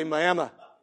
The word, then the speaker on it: Miami: Looks like Miami, Florida. But in Oklahoma, we don't know how to pronounce things correctly, so we say Miami.